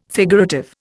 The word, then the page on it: figurative: Indian